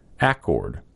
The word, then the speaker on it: accord: By the way, pronounce accord with an accent on the first syllable, accord.